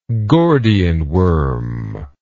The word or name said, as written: Gordian worm